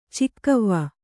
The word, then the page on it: ಚೌಗಾವೆ: kannada; caugāve